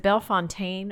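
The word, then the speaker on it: Bellefontaine: It depends on who you are. If you're from Belle Fountain, it's Belle Fountain. That's how you say it. I have people say Belle Fontaine. I think that's a nice way of saying it, but I think the feeling of Belle Fountain is definitely Belle Fountain.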